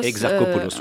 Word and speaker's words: Exarchopoulos: Donc c'est Adèle Exarchopoulos ou Exarchopoulos. – Exarchopoulos, euh, tout à fait. si Bien on joué.